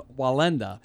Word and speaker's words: Wallenda: Did I say that right? Valendez? – Valenda. Valenda. – W-A-L-L-E-N-D-A. Right. Okay.